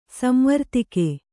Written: ಸಂವರ್ತಕ

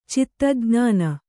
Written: ಚಿತ್ತಜ್ಞಾನ

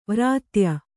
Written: ವೃಥಾಯ

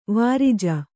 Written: ವಿರಜ

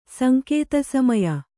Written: ಸಂಕೇತಸಮಯ

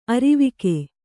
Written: ಅರಿವಿಕೆ

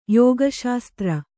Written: ಯೋಗಶಾಸ್ತ್ರ

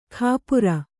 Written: ಖಾಪುರ

